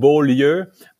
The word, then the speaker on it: Beaulieu: We pronounce it Beaulieu, but the pronunciation in English is non-existent. So it's kind of a gymnastic for you guys to pronounce it right.